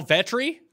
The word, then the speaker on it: Vetri: Sal Vetri? It's pretty. Vetri. All you have to do is say it how it's spelled, and you're good to go.